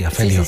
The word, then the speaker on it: afelio: afelio, ¿no? Pronunciada así, afelio.